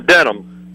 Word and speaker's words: Denham: Denham. – Spell it. – D-E-N-H-A-M.